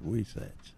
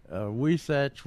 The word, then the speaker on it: Weesatche: It's spelled W-E-E. – Yeah, I saw that. – S-A-T-C-H-E. Somebody knew how to spell that, Wesatch. – I'm going with the H spelling. – That helps all us foreigners figure out what's going on. He said Wesatch was, and spelled W-E-E-S-A-T-C-H-E, was founded around the year 1850 under the name of Middletown, being halfway between Clinton and Goliad.